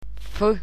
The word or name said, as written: f